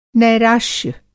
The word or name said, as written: नैराश्य